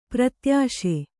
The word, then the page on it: ಪ್ರತ್ಯಾಶೆ: kannada